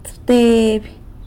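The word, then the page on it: tsëbteby: San Lucas Quiaviní Zapotec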